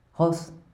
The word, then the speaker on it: Goss: So the Spanish G and J sounds are pronounced as H, so that's why it is especially disconcerting when you have a combination of those, as in common general quotes.